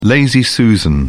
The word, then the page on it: lazy susan: British English